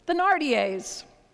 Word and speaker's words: Thénardier: Thénardiers.